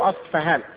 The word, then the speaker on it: أصبهان: أصبهان عليهم الطيالس. الدجال يتبعهم 70,000 من يهود أصبهان، أو أصفهان تنطق بالبي اللي أشبه بالفي الإنجليزية أو بألفاء.